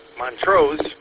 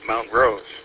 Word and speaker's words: Montrose: the real question is how you would pronounce the city just to the west of it, of Clio, which is Montrose, or if you're from Montrose, you would say Mount Rose, as far as I know.